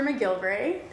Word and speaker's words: McGillivray: I am so excited today to welcome my guest, Dr. McGillivray.